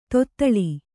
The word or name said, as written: ದತ್ತೆಲೆ